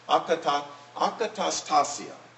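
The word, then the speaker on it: akatastasia (ἀκαταστασία): akatastasia. Akatastasia. Sounds almost Hawaiian because of the repetition, right?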